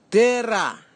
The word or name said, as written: tera